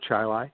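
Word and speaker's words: Chili: Rhymes with highlight. I grew up on a dirt road called Stallman Drive. It was off of Ballantyne Road, south of the city of Rochester, in the town of Chile.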